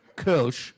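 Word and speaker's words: Kölsch: As long as you can pronounce it right. Kolsch.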